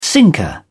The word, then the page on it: sinker: British English